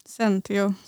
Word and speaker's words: Zentio: Hur uttalar man det? – Centio.